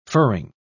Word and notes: furring: British English

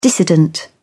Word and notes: dissident: British English

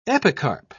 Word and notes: epicarp: British English

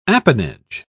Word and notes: apanage: British English

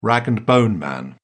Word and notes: rag-and-bone-man: British English

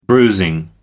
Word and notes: bruising: American English

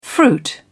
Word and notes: fruit: British English

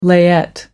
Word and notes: layette: American English

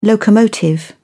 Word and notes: locomotive: British English

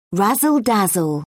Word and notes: razzle dazzle: British English

